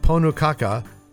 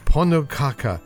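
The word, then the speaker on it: Pönnukaka: Iceland has their ponukaka. Ponukaka. Oh. P-O-N-N-U-K-A-K-A. How would you pronounce it if you don't live in Iceland? Ponukaka, which is a crepe-like pancake cooked on a very special Icelandic pancake pan.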